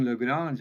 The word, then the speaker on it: Legrand: Well, yeah, Legrand, for people who aren't familiar with it, and I have to say thanks for saying Legrand, just the French way that it should be pronounced.